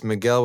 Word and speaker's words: Mikel: I always have to say it's Miguel with a K.